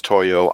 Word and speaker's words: Toyo: T-O-Y-O does, in fact, rhyme with yo-yo.